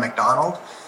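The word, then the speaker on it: MacDonald: For the record, John McDonald. Last name is spelled M-A-C, capital D-O-N-A-L-D.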